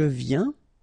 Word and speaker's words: viens: Je viens. Final S not pronounced, okay? And when you combine this ien, you get this yin, yin.